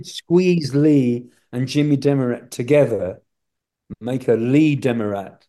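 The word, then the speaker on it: Demaret: Yes, Jimmy Demerit. Americans pronounce it Demerit.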